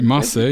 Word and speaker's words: Marseille: He is from Marseille. Is that did I say that correctly? – Marseille, yes, in the south of France, near the Marseille.